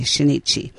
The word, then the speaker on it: Shinichi: Shinichi.